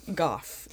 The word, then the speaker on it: Goff: Goff. Denise Goff. Rhymes with cough.